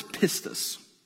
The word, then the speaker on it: pistis: Make sure you pronounce it fully, okay? The word is pistis. You know what I'm saying? Like, you got to say it. all the way. Pistis. That's the Greek word for faith.